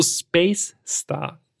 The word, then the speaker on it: Space Star: Also Space Star. Es mit Betonung auf Space.